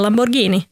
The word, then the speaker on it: Lamborghini: Det heter Lamborghini, och det ser man för det finns ett H efter G, vilket betyder att det ska uttalas som ett G.